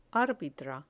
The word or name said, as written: arbitra